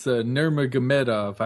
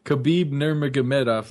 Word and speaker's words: Nurmagomedov: Yes, he could, and it's Nurmagomedov. I finally learned how to say it. – Say it again. – Khabib Nurmagomedov.